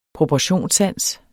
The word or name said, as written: proportionssans